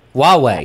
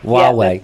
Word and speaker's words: Huawei: Huawei, if I'm saying that correctly, they're basically just Huawei. – Huawei. Okay, so it's there the H-U at a W. Is that correct? – Okay. – Huawei. – Yeah, that's better.